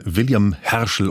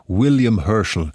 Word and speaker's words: William Herschel: Sinfonie von William Herschel oder William Herschel. Das kann man so oder auch so aussprechen.